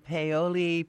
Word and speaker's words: Paoli: We've got Jackie calling from Paoli, Pennsylvania.